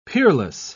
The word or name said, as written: peerless